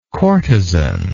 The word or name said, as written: courtesan